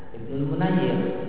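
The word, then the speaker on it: Ibnu Munayyir (ابن المنير): Berkata Al-Hafidz Ibnu Hajar, berkata Ibnu Munayyir, yang benar bacanya Ibnu Munayyir.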